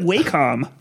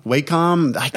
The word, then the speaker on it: Wacom: I can never remember how to pronounce it. I pronounce it differently each time. – I always said it Wacom. – Wacom. – That was my one. And then everyone was always like Wacom.